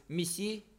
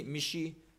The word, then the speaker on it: missi: Donc, au lieu de missi, vache, dans ces dialectes, on dit michi.